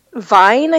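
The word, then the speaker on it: Wein: Elizabeth Vine, I think is how it's pronounced. Even it's W-E-I-N, but it, you know, it's German.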